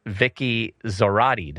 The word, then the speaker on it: Vicky Zorati: But we have with us today our brother, Mike, and our sister, Vicky Zorati. Did I say it right? – You did good. – Like Maserati. I'll never forget that.